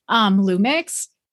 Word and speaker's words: Lum!x: One in particular, And I hope I pronounce this correctly, Lumix.